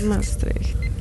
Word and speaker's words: Maastricht: And I think it's pronounced Maastricht. – Maastricht, makes sense.